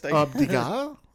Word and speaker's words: Degas: So it's Rupert Degas, I think that's how you pronounce it. Or, you know, like the artist. – Degas? Yeah. Like Degas. Rupert. – D-E-G-A-S.